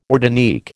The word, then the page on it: ortanique: American English